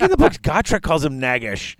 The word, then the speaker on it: Gotrek: Actually heard it pronounced by the guy who wrote the books, and if you listen to the audiobooks, it's Gotrek. Like, that's the pronunciation.